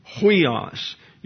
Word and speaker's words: huios: and so it's spelled huios, your hissing sound for the H and the U.